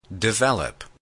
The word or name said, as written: develop